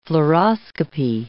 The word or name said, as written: fluoroscopy